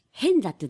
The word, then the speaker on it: 平安座: 中学の時の友達が、はい、うるま市の、変座っていうところに、平安座と書いて、変座と読むんですけども、そこにおりまして、もう前から誘われていたのが、やっと実現したっていうところです。